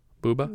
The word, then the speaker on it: Bubba: The co-editor, Pasquale Bubba, or Pasquale Bubba, B-U-B-A, how would you pronounce that? Bubba? Bubba? – Yeah, Bubba.